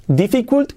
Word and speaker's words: difficult: Difficult las i's como es. Difficult. It seems difficult. It seems difficult. Es curioso. Tenemos aquí tres Is y dos Es. Sin embargo, las i's se pronuncian como es y las dos Es se pronuncian como una I.